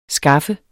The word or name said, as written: skaffe